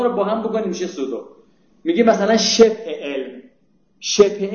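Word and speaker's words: pseudo: من گفتم پزودو، یه بنده خدا تذکر داد که این پزودو نیست، زودو تلفظی ولی پی داره، سودو من، سودو تو انگلیسی میدونی چیه دیگه، یه کلمه اینجوری بود اگه اشتباه نکنه اسپلش، حالا شاید نمیشه او بوده چی.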